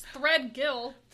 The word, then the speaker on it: Threadgill: Anyways, Ronnie Thigadelial. – Threadgill? – Yeah, that's what I said. – No. How is that even hard to pronounce? It's Threadgill. – Threadagill.